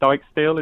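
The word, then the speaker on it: Dijksteel: And I like the look of, is it Dykesteel? Is that how you say his name? – Yes, like the look of him last season.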